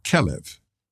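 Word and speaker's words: kelev (כלב): The Hebrew word for dog is kelev. As Hebrew has no vowels, the word is actually spelled K-L-V. Those three letters can also be pronounced as kalev, which means like the heart.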